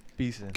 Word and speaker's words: Beeson: Heber Jeddy Benson? – Beeson. – Gosh Beeson.